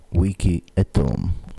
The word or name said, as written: wiki at home